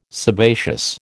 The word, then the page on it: sebaceous: American English